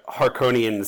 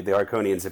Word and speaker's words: Harkonnens: I think they saw that there would be like a war with the Harkonnens if they couldn't bring peace through marriage. – I gotta correct you. Just say Harkonnens.